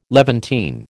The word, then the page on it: Levantine: American English